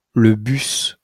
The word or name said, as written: le bus